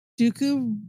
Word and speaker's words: Dooku: Dooku remarks... direction of the starship. – No, you're fine. I'm just... – Starship. – Dooku, you said it right.